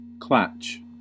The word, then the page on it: klatch: American English